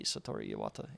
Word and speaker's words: Satoru Iwata: So the third installment of Super Smash Brothers series, it was announced at pre-E3 2005 press conference by Nintendo president Satoru Iwata. Oh, I can't even say his name. Oh, I'm so sorry, Satoru Iwata.